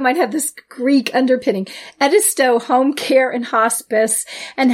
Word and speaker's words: Edisto: How do I pronounce that? – It's Edisto. – Edisto. It's a good thing I asked you.